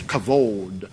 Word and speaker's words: kavod: And I like to look up glory, and there's a few words for glory, and it's kavod. They pronounce it on my little web thing. I look at it, it's kavod. But it means glory, it means fullness, it means all those things.